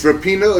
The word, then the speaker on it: Rapinoe: But also hotshot Megan Rapinoe making headlines. – Funny how you say Rapinoe, not Rapinoe.